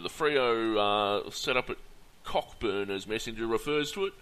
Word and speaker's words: Cockburn: and it's their elite training facilities at Cockburn,